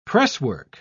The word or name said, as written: presswork